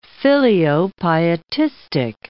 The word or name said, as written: filiopietistic